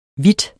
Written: vidt

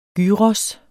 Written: gyros

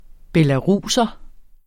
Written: belaruser

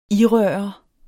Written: irøre

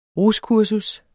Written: ruskursus

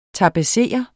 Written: tapetsere

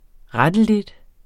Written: rettelig

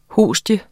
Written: hostie

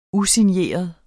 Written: usigneret

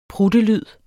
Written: pruttelyd

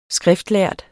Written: skriftlærd

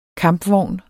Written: KAMPVOGNE

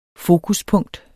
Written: fokuspunkt

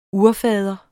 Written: urfader

